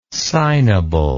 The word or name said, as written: signable